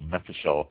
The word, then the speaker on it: mevushal: Another term used for kosher is, and I apologize if I mispronounce this, mefushal, M-E-V-U-S-H-A-L, mefushal.